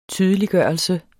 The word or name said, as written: tydeliggørelse